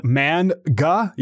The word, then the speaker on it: manga: It's called manga. Yeah, manga. – Manga. That's right. Yeah. That's the correct pronunciation.